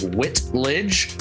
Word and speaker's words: Whitledge: And it was, I couldn't pronounce like the ch sound. So having the last name of Whitledge was horrendous for a long part of my school days.